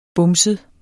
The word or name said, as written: bumset